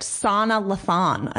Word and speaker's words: Lathan: but there is huge rumors that it's that Sana Latham girl. Is that how you say her name? – She's second favorite. Sana Lathan, I think it is.